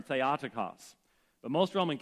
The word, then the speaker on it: Theotokos: Now, modern Greek speakers would say Theotokos, but I was taught what's called Erasmian pronunciation, and Erasmian pronunciation actually allows you to figure out one word from another word, and it differentiates the pronunciation of the vowels.